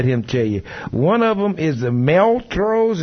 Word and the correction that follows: Montrose: Is how you say that? – Montrose coal fired plant causes 30.